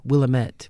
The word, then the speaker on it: Willamette: And I say this every, you know, whenever I get that question, because a lot of people is like, it's not pronounced Willamette. Well, it is.